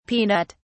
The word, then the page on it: peanut (s): British English